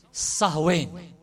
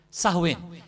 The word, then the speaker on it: Samhain: S-A-M-H-I-H-A-I-N. They call it Samhain. But it's spelt, or they pronounce it, Sahwain. Sahwain. S-A-H-W-I-N.